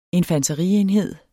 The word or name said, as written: infanterienhed